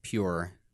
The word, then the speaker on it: Pu'er: In the video, he was saying like pu'er, just like he almost like made it one syllable almost. – Yeah, well, I've seen people here pronounce it pure, but I'm just going off of the like the pinyin.